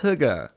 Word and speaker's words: hygge: High gee. – It's also, by the way, it's pronounced hygge. So it's not like it's hygge. Hygge.